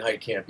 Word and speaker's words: Heitkamp: And then there's another guy who's really, really good who plays like your friend, Brian Heitkamp. Is that how I pronounce it? – Brandon Heitkamp, yes.